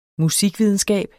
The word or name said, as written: musikvidenskab